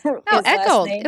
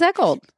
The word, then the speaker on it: Eggold: And I was in the closet, and we were all talking about Ryan's name, and you said Agor or something instead of his Eggold. It was Eggold.